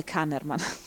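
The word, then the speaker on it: Kahneman: Si fa risalire alle ricerche degli psicologi Amon Tversky e Daniel Ankerman, che no, scusate, Kannerman.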